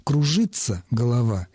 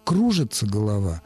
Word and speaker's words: кружится: «Кружится голова» — это старшая норма, которая сегодня звучит как абсолютно устаревший акцентный вариант. «Кружится голова» — это молодая норма, более активная. Сегодня она является основным вариантом произношения.